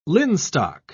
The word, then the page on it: linstock: British English